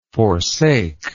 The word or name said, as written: forsaker